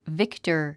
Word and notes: victor: víktə r; ビクタァ

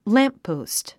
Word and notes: lamppost: lǽmppòust; ランポゥスト

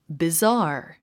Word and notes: bizarre: bizάː r; ビザー

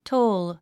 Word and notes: toll: tóul; トーゥ

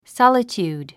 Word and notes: solitude: sάlətjùːd; ソロチュード

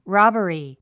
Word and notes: robbery: rάbəri; ラバリィ

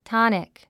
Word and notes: tonic: tάnik; トニク

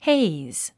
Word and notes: haze: héiz; ヘイズ

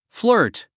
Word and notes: flirt: flə́ːrt; フラート

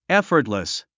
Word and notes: effortless: éfərtlis; エッフォトレス

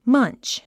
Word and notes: munch: mʌ́ntʃ; マァンチ

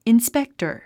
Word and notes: inspector: inspéktə r; インスペクタァ

